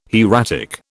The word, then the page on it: hieratic: British English